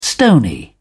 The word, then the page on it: stony: British English